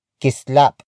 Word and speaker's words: kislap: Word for word, it's may kislap sa iyong mata.